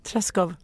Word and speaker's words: Treschow: Det är så här man uttalar det. Så bara, Treschow. – Treschow.